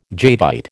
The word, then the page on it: jadeite: American English